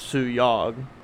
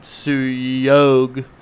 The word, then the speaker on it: Suyog: He said his name is Sue Yog, not Sue Yog but Andrew pronouncing it for us said Suyog, and he spelled hoagie, so S Y U H O A G I E.